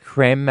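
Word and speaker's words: crème: I don't know how to say this word. C-R-E-M-E, with an accent acute on the E, the first creme. – E. – Creme. – So that's that makes it creme, does it? – Creme.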